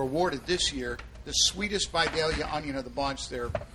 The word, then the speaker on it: Vidalia: It's an honor to get to try those Vidalia onions.